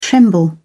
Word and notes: tremble: British English

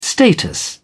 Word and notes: status: British English

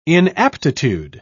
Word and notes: inaptitude: British English

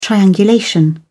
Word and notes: triangulation: American English

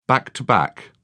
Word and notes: back to back: British English

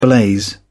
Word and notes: blaze: British English

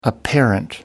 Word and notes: apparent: American English

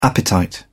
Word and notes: appetite: British English

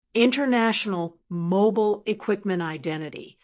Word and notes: international mobile equiqment identity: American English

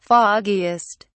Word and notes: foggiest: British English

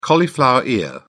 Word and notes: cauliflower ear: British English

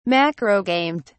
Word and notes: macrogamete: British English

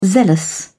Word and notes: zealous: British English

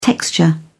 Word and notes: texture: British English

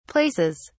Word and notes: places: British English

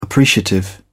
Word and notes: appreciative: British English

